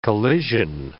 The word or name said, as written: collision